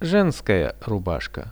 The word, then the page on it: zhenskaja rubashka: Russian